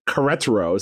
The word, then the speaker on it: Querétaro: There was a match between Querétaro. Is that how we're pronouncing it now? – Yes.